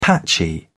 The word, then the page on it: patchy: British English